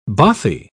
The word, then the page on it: bothie: British English